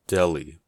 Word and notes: deli: American English

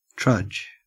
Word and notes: trudge: Australian English

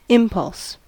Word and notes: impulse: American English

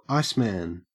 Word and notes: iceman: Australian English

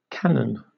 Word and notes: canon: British English

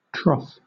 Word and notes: troth: British English

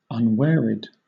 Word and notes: unwearied: British English

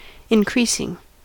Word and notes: increasing: American English

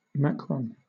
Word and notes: macron: British English

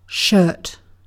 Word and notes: shirt: British English

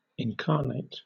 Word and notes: incarnate: British English

